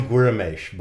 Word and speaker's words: Guimarães: It's spelled G-U. Essentially Guramesh, but that's only an approximation.